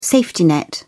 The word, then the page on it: safety net: British English